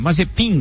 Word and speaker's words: Mazepin: A Haas anunciou essa noite, mas é, na manhã lá no Bahrein, o piloto russo Mazepan, Mazepin, na verdade Isso.